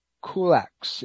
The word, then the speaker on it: Kulacz: But, you know, there's also a guy by the name of Dr. Robert Kulax. You pronounce or spell his last name as K-U-L-A-C-Z, and he wrote a book called The Toxic Tooth,